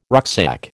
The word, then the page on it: rucksack: American English